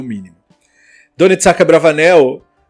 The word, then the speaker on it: Abravanel: Bom, nós falamos que íamos falar um pouquinho do Abravanel, Don Itzhak Abravanel.